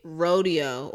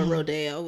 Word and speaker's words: Rodeo: Panini, Family, Kick It, Rodeo, or Rodeo, whichever you pronounce, or however, whatever it means.